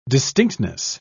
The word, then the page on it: distinctness: British English